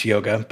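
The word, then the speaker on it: Jóga: I, I, think it's Joga by Bjork. It's spelled with a J.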